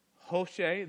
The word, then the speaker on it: Hoshea: One is from the tribe of Judah, Caleb, son of Jephunneh, and from the tribe of Ephraim, and I've heard it pronounced a few ways, it's Hoshea, the son of Nun.